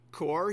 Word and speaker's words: corps: There are different groups within the military that we describe as corps, and that's not C-O-R-E, corps. Here's a spelling lesson for everybody. That's C-O-R-P-S. Now, I can't explain for you why the French don't want us to pronounce the last letters on all of their words. So we just say corps,